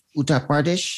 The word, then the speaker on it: Uttar Pradesh: Uttar Pradesh? – Yes.